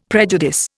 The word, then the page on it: prejudice: Indian